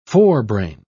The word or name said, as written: forebrain